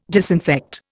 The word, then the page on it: disinfect: Indian